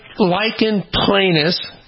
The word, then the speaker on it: Lichen planus: Lichen planus is how it's pronounced.